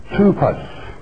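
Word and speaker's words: tupos (τύπος): The Greek word, let's transliterate it back to the Greek from English, T-U-P-O-S, pronounced in the English tongue or the Greek tongue, tupos, tupos.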